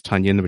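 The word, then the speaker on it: Tonyan: Because it's not Tunyon, it's Tunyon, which rhymes with Funyon.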